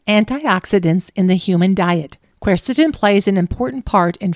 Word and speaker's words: quercetin: Today, let's grab a hold of the flavonoid quercetin.